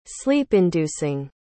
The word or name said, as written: sleep-inducing